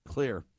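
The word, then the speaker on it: Xlear: Clear, spelled with an X, Clear.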